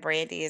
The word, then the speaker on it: Brandie: And Nicole is spelled N-I-K as in kite, O-L-E. And Brandy is spelled B-R-A-N-D-I-E.